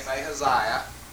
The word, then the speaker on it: Ahaziah: Okay, learn to say his name, Ahaziah.